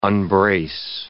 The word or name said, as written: unbrace